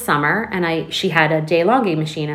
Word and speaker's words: DeLonghi: My DeLonghi, that's how you pronounce it. I looked it up.